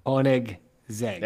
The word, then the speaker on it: Koenigsegg: KOW-NEEGS-EGG. – Yep. – KOW-NEEGS-EGG. – Nope. – Zeg? – There's a G in the middle. KOW-NEEGS-EGG. – KOW-NEEGS-EGG.